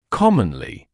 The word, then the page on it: commonly: ‘kɔmənlɪ; ‘комэнли